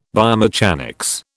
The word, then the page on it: biomechanics: British English